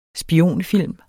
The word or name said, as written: spionfilm